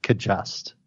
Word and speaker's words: Cajuste: Last name is C-A-J-U-S-T-E. – Cajuste. Is that what it is? I would have butchered that. – I believe. He did butcher it. That is not how you pronounce it. – Oh. Well, then I'll take a stab at it. I'll just go with the phonetic. Kajust. – That is correct. Yodney Kajust.